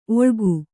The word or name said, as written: ಒಳ್ಗು